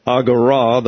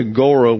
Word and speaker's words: agora: It's not an omega, but an omicron, so it's agora. The last syllable is pronounced in Greek. So we would not say agora, we would say agora. But nobody's going to do that, so you don't fight City Hall. So it's agora to you.